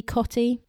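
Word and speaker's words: Cottee: Tony Cotty, did I pronounce that right? – Yeah, he's one of the Sky reporters, actually, Cotty.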